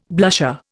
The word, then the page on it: blusher: Indian